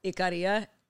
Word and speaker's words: Ikaria: Ikaria. I actually do think I said that right. Yeah. – What's it called? – Ikaria. I-K-A-R-I-A.